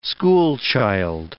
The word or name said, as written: schoolchild